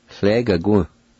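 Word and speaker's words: tlegaquu: Sle, ga, gu. Sle, ga, gu. Sle, ga, gu.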